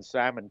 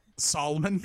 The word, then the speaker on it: salmon: You know, at this point, you might as well go ahead and pronounce the L in salmon because nothing else matters. – Can you say that for me just once? – Solomon? – Salmon?